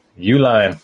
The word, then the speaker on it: Uihlein: Uihlein? – Uihlein. – I got to write that down. I have a draft show tomorrow. – Try to spell it. Uihlein. – Uline? – Close. Uihlein.